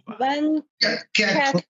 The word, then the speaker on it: Van Kerckhove: Van Kerkhove.